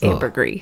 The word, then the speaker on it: ambergris: It's like Illinois or Illinois. – Oh, it's definitely silent Illinois. – S. – Yeah, it's ambergris.